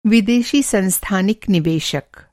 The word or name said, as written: सांस्थानिक